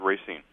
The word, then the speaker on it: Racine: Yeah, and I pronounce it Racine.